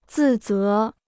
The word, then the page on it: 自责: zì zé